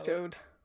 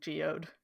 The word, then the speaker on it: Jeod: Jeod. – Jeod. – Even though from the way it's spelled, it looks like it should be pronounced like geode.